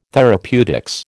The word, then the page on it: therapeutics: American English